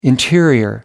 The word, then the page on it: interior: American English